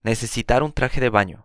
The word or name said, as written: necesitar un traje de baño